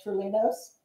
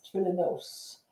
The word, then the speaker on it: Trulinos: And by the way, if you're a fan of mine and you've watched past 92 episodes and I've pronounced myself Trulinos, I was corrected when we went to Greece that I have been mispronouncing my original Greek heritage name. It is Trulinos.